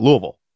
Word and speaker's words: Louisville: That's how you say it. Louisville.